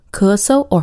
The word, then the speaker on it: 咳嗽: And it can also be pronounced 咳嗽。咳嗽。If you look at the two characters individually, it's 咳, second tone, and 嗽。Fourth tone. However, when it's put together, the second character can sometimes be pronounced as a neutral tone. So 咳嗽, 咳嗽, or 咳嗽.